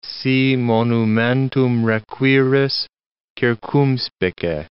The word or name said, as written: si monumentum requiris, circumspice